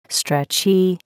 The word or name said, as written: stretchie